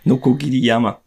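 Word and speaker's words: Nokogiriyama (鋸山): Nokogiriyama.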